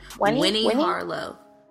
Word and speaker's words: Winnie Harlow: Weenie. Oh, am I saying her name? – Winnie Harlow. – Like, there you go.